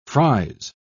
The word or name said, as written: fries